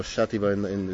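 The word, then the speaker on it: Xàtiva: Chatiba or Chatiba is how it's pronounced in the local language.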